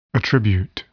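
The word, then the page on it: attribute: verb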